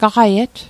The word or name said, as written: gaqiet